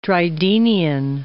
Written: Drydenian

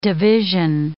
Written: division

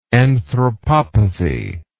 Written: anthropopathy

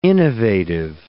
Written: innovative

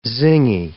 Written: zingy

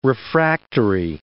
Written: refractory

